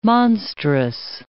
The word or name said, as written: monstrous